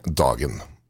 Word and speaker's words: dagen: Dogen is day? – With the day is what they say. – Dogen is day? – Dagen is the day, yes.